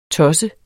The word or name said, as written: tosse